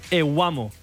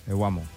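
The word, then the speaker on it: Evbuomwan: I sobretot, de cognom bastant impronunciable, no? – Tosan Ewamo. – Ewamo.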